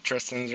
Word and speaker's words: Tristan: Yep, Tristan's right.